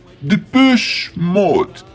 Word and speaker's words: Depeche Mode: É, então, já que é um nome francês, a pronúncia correta seria Depeche Mode.